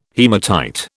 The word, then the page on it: haematite: British English